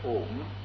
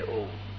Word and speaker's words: Om: And because the whole language is included, all Krishna's names are included in om. A, u, ma. Om. See how I pronounce. Om. This is vibration.